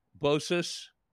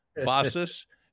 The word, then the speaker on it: Bossis: You want to go to Google and type in Anthony Bosis, or with respect to his dear dad, Bosis. It's pronounced B-O-S-S-I-S, Dr. Anthony Bosis or Bosis.